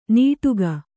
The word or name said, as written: ನೀಟುಗ